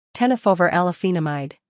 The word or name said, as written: Tenofovir Alafenamide